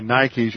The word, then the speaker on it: nike: And it is, according to the system of pronunciation I was taught in Greek, it should be pronounced nike.